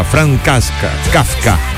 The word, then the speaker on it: Kafka: Un 3 de julio de 1883 nacía en Praga, Checoslovaquia, en esa época Franz Kafka.